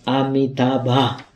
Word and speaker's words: Amitabha: Das ist chinesische Aussprache. Auf Sanskrit sagt man Amitabha.